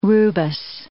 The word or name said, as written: rubus